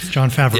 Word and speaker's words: Favreau: John Favreau.